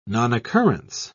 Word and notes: nonoccurrence: British English

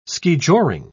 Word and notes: skijoring: British English

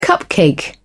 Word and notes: cupcake: British English

